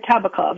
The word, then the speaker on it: Kabakov: Miriam, am I pronouncing your last name correctly? – Yeah, that sounds right. Kabakov, that's how I say it. We all say it a little bit differently.